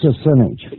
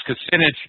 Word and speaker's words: Kucinich: Dennis Kucinich. – Yeah, I can never pronounce it right. Hey, yeah. Dennis Kucinich.